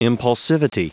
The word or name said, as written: impulsivity